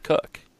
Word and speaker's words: Koch: spelled C-O-C-H, but it is pronounced Cook as far as I know, had his first major league start, filling in now that Taijuan Walker is down for the Diamondbacks.